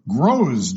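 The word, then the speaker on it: grosz: Variant spelling is G-N-O-S-I-S. Goosey, G-O-O-S-E-Y, resembling a goose. And, of course, the variant spelling is G-O-O-S-Y. Groz, G-R-O-S-Z-E, is a Polish coin, and you can spell it without the E.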